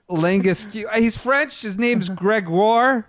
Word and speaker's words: Langasque: Someone who I've originally called Romain Langasque. I'm told it's Langasque.